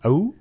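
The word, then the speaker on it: Ou: Oh? – Yeah.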